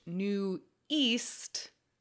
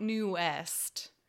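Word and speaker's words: NU'EST: Their name is stylized in all caps, N-U-apostrophe-E-S-T, and it stands for New Established Style Tempo, but it's actually pronounced New East, not New Est, but I feel like I personally can't train myself to say Newest.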